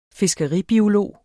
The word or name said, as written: fiskeribiolog